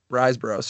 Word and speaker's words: Riseborough: Andrea Riseborough, I think that's how you say her name. – Andrea Riseborough. – Riseborough, sorry.